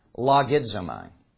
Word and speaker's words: logizomai: I'm going to pronounce it only because you can hear a bunch of English words in it. Logizomai. You can hear log, logarithms, logistics.